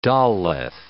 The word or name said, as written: daleth